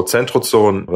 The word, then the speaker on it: Centrozoon: So Centrozoon, that's the German pronunciation.